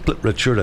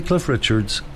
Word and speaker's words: Cliff Richard: On this day in 2003, Sir Cliff Richard, and I just said Cliff Richard, I didn't say Cliff Richards, Sir Cliff Richard, the wine that he made on his Algarve estate started a UK supermarket battle.